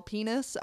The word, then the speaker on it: penes: Apparently, the proper grammatical word for plural penis is penis. – With a Z? – It's spelled P-E-N-E-S, but phonetically you would spell it like P-E-E-N-E-E-Z.